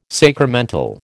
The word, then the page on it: sacramental: American English